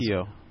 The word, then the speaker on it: Keogh: There's Mike, is it Keogh or Keogh, or how do you pronounce Keogh. – It? Keogh. Keogh. So G-H is silent.